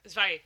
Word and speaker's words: Zvei: Am I pronouncing that right? Zvei. – Zvei, yeah. – Zvei. Yeah. That's it. It's German. – It's German.